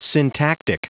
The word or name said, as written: syntactic